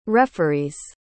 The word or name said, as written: referees